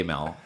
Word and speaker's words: Amell: Like if there was one word that followed your name, Robbie, Robbie Amell, is that how you pronounce it? – Amell.